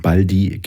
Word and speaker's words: Baldi: Ja, ich habe überlegt, ich hatte auch einen zweiten Einstieg gewählt, dass du nämlich gar nicht Baldi heißt, sondern Baldi, also diese Betonung auf dem I hinten raus. Und wir das eigentlich seit 25 Jahren falsch sagen, stimmt das? – Ist Quatsch.